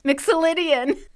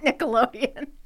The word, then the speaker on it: Mixolydian: Okay, Mixolydian. – Not Nickelodeon. – I didn't say Nickelodeon. I said Mixolydian. – Okay, say it again. – Zion Mixolydian. – Okay. – But the middle name is spelled M-I-X-O-L-Y-D-I-A-N. Mixolydian.